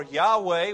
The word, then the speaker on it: Yahweh: And what that means to us is that God or deity or Jehovah or Yahweh, which is the correct pronunciation, Yahweh must be treated with great respect.